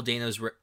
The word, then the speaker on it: Dano: After Paul Dano's Riddler, I'm very curious who it's going to be.